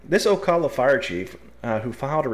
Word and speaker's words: Ocala: I called it Osceola last time, and he's saying it's Ocala. So we're going to go with Ocala.